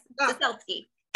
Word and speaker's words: Ciesielski: Yes, Ciesielski.